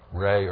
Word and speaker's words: Ra: Re or Ra, it's pronounced.